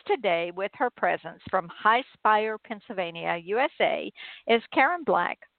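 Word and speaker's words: Highspire: Did I say that wrong? High Spire or High Spire? You had it.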